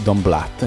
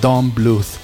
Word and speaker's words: Don Bluth: È un disegno di Don Bluth, quindi, che non si dice Don Blatt, mi raccomando, se non si offende. È Don Bluth